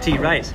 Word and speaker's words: Therese: No, you got to say it like T-Race.